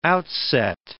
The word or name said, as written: outset